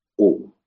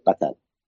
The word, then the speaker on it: قتادة: بعض الناس يخطئ وينطقها بضم القاف، قو، صحيح قتادة، سواء أبو قتادة الأنصاري أو المفسر قتادة بن دعامة، الحارث ابن، صحيح الحارث، لكن الحارث ابن من؟